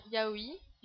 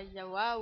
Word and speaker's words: Yaoi: Déjà sa prononciation, hein, qui se dit bien Yaoui, et pas Yawa ou Yaoi. – En trois syllabes.